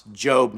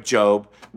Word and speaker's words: Job: Spelled J-O-B, pronounced Job, not Job.